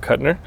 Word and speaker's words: Kutner: So speaking of Matt Kelly and the things that he brings together, this episode, I'm sitting down next to Mr. Rob Kutner.